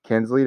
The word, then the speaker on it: Kinsley: Most people just say Kingsley instead of Kinsley. – Yeah, my name's spelled T-A-I, so I'm familiar with wrong pronunciations.